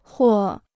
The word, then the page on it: 或: huò